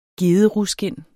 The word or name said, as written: gederuskind